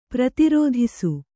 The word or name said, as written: ಪ್ರತಿರೋಧಿಸು